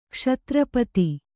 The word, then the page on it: ಕ್ಷತ್ರಪತಿ: kannada